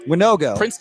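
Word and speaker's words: Wanogho: How do you say his last name? – Winogo. Prince Tega Winogo.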